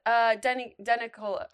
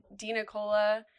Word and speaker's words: Denicola: It's Denicola. I actually have no idea how to say it, I'm going to be honest, but it's, I have always said Denicola. But I don't know. It's Italian, so it's up for interpretation, truly.